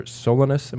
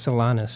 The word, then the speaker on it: Solanus: Also has a piece coming up on what might be tentatively called Detroit's patron, about Father Solanus. Am I pronouncing that right? – I think it's Solanus.